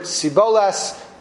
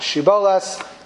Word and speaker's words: shibboleth: The people of Ephraim was used as a code to determine who was from Ephraim or not, because Ephraim could only pronounce the word Siboles, not Shiboles, as it is said in the Navi.